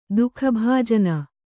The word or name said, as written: ದುಃಖಭಾಜನೆ